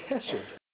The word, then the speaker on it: chesed: And it comes from the Hebrew word chesed. C-H-E-S-E-D is the way you would spell that transliteration in English. Chesed.